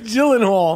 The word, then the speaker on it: Gyllenhaal: I think it's Glenthal, right? How do you the way it's written is hard to fucking pronounce that shit. – Jake Gyllenhaal.